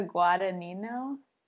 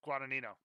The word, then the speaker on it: Guadagnino: I say Luca Guadagnino. – Guadagnino.